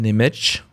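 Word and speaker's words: Němec: Donc les films dont j'ai parlé, c'est Les Diamants de la Nuit de Yann Nemetch, ça s'écrit Nemec, N-E-M-E-C, et Les Petites Marguerites de Vera Shtilova, qui est peut-être le film le plus identifié de cette collection.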